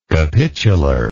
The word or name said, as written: capitularly